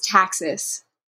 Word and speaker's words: Taxis: Yes, it's Taxis.